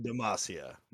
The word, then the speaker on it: Demacia: Demacia. – Yes, said it right. – Definitely right. Demacia.